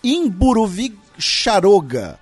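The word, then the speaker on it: Mburuvicha Róga: Ele disse que a pronúncia é algo próximo de Imburuvicharoga. Tá? E ele, enfim, ele falou do M Boimirim por causa do MB e tal.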